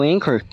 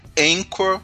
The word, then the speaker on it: Anchor: Então houve o Marcelinho e para quem ficar na dúvida, porque eu conheci a plataforma recentemente, Anchor se escreve Anchor com CH. A-N-C-H-O-R. Então procura a gente lá no Encore também, que estamos lá e como o Marcelinho diz, todas as nossas redes sociais já estão conectadas por lá.